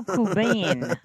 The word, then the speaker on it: Qubein: Nido Kubin. Q-U-B-E-I-N.